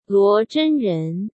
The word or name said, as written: Luo Zhenren